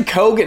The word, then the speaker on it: Keoghan: Barry Keoghan? – Keegan? – It's actually Keoghan. It's way easier than we all made it hard.